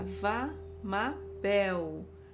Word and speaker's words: Vamabel: O é é como se tivesse um acento agudo. Então fica vá ma bel. Esse bel é com L no final, o som de L, ok?